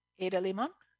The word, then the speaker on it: Ada Limón: Give it up for Instructions on Not Give Up by Ada Limón. Did I say it wrong? – Ada Limón? I think you said it right.